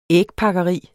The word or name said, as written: ægpakkeri